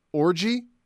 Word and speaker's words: Orji: And I think, oddly enough, this is how you pronounce his name. Alex Orji. And O-R-J-I? – O-R-J-I, yeah.